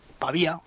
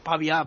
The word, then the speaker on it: Pavia: Bueno, pues la Piazza Victoria, como te he dicho, es la plaza principal de Pavía, o Pavia, ¿no? Como dicen los italianos. Bueno, a ver, yo te voy a pronunciar Pavia para como estamos en Italia, pues para que la gente ya se vaya acostumbrando, ¿no? – Sí.